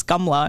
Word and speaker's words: Kamala: why are you pronouncing it like that? You're Indian. She's like, it's Kamala. And I'm like, that's how everybody's pronouncing it.